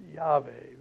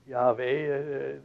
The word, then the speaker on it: Yahweh: And so this, again, is another complicated matter, but the best educated guess is that the pronunciation of it was Yahweh, Y-A-H-W-E-H, with however strange the sound, huh? Yahweh, nevertheless, that apparently, it's been argued, disputed, but the majority of scholars would agree that that was the proper pronunciation, Yahweh.